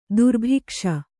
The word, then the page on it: ದುರ್ಭಿಕ್ಷ: durbhikṣa